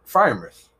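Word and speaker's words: Freiermuth: I think man's let's try to pronounce his last name. – Frymuth? – Frymuth. – Yeah. – Very nice.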